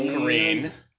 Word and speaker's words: Korine: Korine. As in you will careen off the road mysteriously if you say his name wrong again.